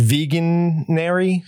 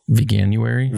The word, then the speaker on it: Veganuary: Veganary. – Veganuary. – Veganuary. I hate that. Sales slump for meat alternatives. Laugh out loud. That makes me so happy. So we talked about it a while ago, Veganuary,